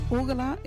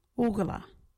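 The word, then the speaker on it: ugarla: Orgula is long, long ago, a while ago, or once upon a time. Orgula.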